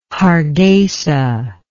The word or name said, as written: Hargeisa